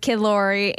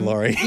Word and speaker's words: Laroi: There's so many, though, but also Stay with Kid Laroi and Justin Bieber. – Kid Laroi, yeah. – So good. – Yes. – I said it wrong. – Kid Laroi.